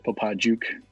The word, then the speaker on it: Popadziuk: Yeah, it's more of a hard J, Popadziuk. It's a Polish.